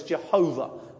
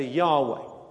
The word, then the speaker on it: Yahweh: Some people refer to it as Jehovah, but we think the pronunciation was better, Yahweh.